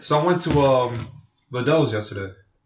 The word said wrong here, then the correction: Lidl: So, it's actually pronounced. It's actually. – Lidl. I was going to say, what the fuck is this? It's Lidl.